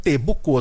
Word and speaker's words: Boucault: Bucô se escreve B-O-U-C-A-U-L-T. Bucô.